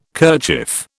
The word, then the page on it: kerchief: British English